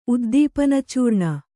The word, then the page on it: ಉದ್ದೀಪನಚೂರ್ಣ: uddīpanacūrṇa